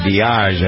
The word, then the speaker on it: Diage: And one of the people responsible for moving the Beatles along to success, lady named Mickey Diage, I believe is how you pronounce her name, D-I-A-G-E.